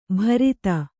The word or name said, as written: ಭರಿತ